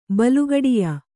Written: ಬಲುಗಡಿಯ

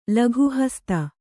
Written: ಲಘುಹಸ್ತ

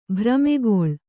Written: ಭ್ರಮೆಗೊಳ್ಳು

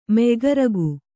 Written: ಮೆಯ್ಗರಗು

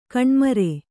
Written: ಗೋಣುಮುರಿ

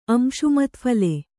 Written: ಅಂಶುಮತ್ಫಲೆ

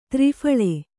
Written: ತ್ರಿಫಲ

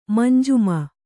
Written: ಮಂಜಿಮ

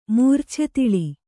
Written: ಮೂರ್ಛೆತಿಳಿ

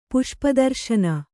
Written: ಪುಷ್ಪದರ್ಶನ

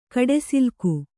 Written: ಕಡೆಸಿಲ್ಕು

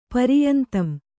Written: ಪರಿಯಂತಂ

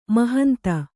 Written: ಮಹಂತೆ

